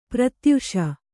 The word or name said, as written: ಪ್ರತ್ಯಾಶೆ